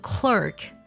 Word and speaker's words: clerk: 还有像职员这个单词会把一二组合读作 r 的音，那这样呢也是比较少见的，读作 clock，而美式的发音呢一二的组合呢读作 r 的音，读作 clerk。